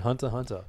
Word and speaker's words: Hunter x Hunter: It's the X is silent. I don't know why it's even there, Hunter, but it's called Hunter Hunter.